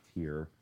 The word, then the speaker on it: TYR: At that time, TYR, which is Tier, is how it's pronounced, Speedo had approached me, but man, I really wanted Nike.